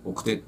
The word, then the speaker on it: 晩成: 晩成と書いて奥手って読むんですが、